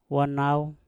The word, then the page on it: wanau: mpur